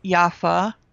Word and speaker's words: Yaffa: On Facebook, I am Yaffa Bacha da Costa, just like you have on your show, mm-hmm. – you know, my name. There's no H at the end of Yaffa, so mm-hmm.